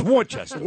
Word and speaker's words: Worcester: It is spelled Worcester. What says Worcester about that? It's Worcester.